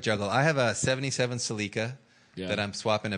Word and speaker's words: Celica: Everyone but there's ignoramuses from America said it the correct way. Celica.